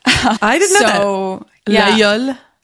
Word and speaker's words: Laguiole: In Aubrac, they make a cheese called L'Aïeul, which is the same as the name of the knife. L-A-G-U-I-O-L-E. You don't pronounce the G,